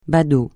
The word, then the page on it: badaud: francais